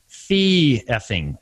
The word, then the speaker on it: fee effing: And so kind of the trend in our industry became what I refer to as fee effing. It rhymes with trucking.